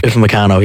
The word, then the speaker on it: Upamecano: That said, if France have a weakness, it's in, how do you pronounce his name? Upman Cow, is it? The centre-back. – Upamecano, yes.